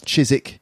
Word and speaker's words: Chiswick: It should be pronounced Chiswick.